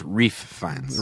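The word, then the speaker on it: Ralph Fiennes: Can I correct that? It's pronounced Reef Fiennes.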